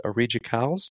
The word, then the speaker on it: Aridja Kawls: It's Aridja Kawls. Is that correctly? – Yeah.